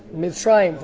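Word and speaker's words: Mitzrayim (מצרים): Mitzrayim, from a language of Tsar, narrow.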